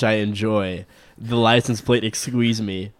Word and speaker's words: XQUESME: I'll spell it out for you first, XQUESME, which phonetically comes out to Exqueeze Me, which he found to be very appropriate for a Corvette, and I agree.